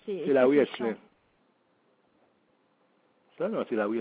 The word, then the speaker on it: Hincelin: Comment ça s'écrit, s'il vous plaît? – Comment? H-I-N-C-E-L-I-N. Comme ça se prononce.